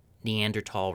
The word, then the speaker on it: Neanderthal: Basically, the long and short of it is places that we've known Neanderthal remains are. And sidebar, I'm going to be saying Neanderthal, which is, according to the Google results, how you actually pronounce it.